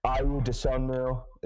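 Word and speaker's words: Ayo Dosunmu: Iwu Desunmu, is that how you pronounce his name? – Yeah, you got it.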